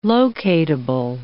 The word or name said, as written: locatable